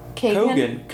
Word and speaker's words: Keoghan: Kagan. – Kagan.